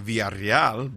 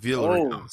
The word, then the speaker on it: Villarreal: Yeah, Villarreal. I mean, if you wanted to say it like all from the native tongue, you can say Villarreal, but Villarreal is good.